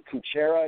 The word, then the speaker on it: Kutchera: My personal page is Brandon, B-R-A-N-D-O-N, last name Kuchera. It's spelled K-U-T-C-H-E-R-A.